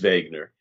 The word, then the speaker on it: Wegener: I've, I've actually pronounced his name Wegener, which I apologize for in the past, but, but his name is Wegener.